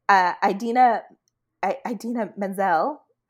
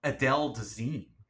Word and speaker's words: Idina Menzel: Something like Adele Dazeem. – Yeah. Like letters. He pronounced letters that are like not in her name.